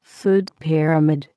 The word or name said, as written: food pyramid